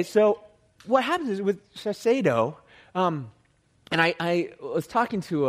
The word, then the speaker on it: Shiseido: How do you pronounce it? Somebody help me. Shiseido? Is that how you pronounce it? Thank you. I get a nod from a guy out here. Thank you very much. That's how you pronounce this. Shiseido.